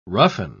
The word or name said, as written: roughen